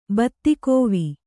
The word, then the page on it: ಬತ್ತಿಕೋವಿ: kannada